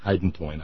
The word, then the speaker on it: hydantoin: Hydantoin. I think I had an extra syllable there.